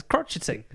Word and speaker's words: crocheting: I said crotchet. I do say crotchet in this interview. I was like, what? It's crocheting.